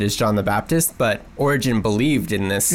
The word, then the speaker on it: Origen: the first person that kind of not only validated my concerned with that, but also pointed out that Origen? Is that how you say his name? – Origen.